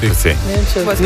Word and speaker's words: Nemčavcih: Nisem siguran, kako se to izgovori. No. Nemčavcih. – Moje so Nemčavci. – Nemčavcih ali Nemčavcih? Nemčavcih. No, nekaj od tega, nič 80, 22, 25.